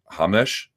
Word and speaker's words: Ramos: Davi, it's spelled Ramos, but I guess it's pronounced Hamosh. It's like Hamesh Rodriguez or something.